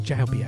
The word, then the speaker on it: Jaubi: And then tribute to MF Doom, Jorby, out of Pakistan. Figaro, Farewell MF Doom, featuring Latinic, and you can pick that up on Bandcamp. I think it's pronounced Jaubi, actually, Jaubi.